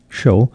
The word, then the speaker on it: Hsiao: An impressive violin concerto by the Taiwanese composer Tyson Shao. That's spelled H-S-I-A-O, Tyson Shou.